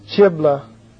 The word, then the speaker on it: Chileab: He's called, if I can pronounce it, Chibla, C-H-I-L-E-A-B. Chileab or something like that. C-H-I-L-E-A-B.